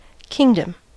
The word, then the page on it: kingdom: American English